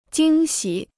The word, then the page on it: 惊喜: jīng xǐ